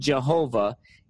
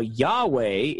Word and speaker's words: Jehovah: The commonly repeated modern idea that the pronunciation Jehovah is a late and incorrect invention, while Yahweh is the true pronunciation of the name, is false.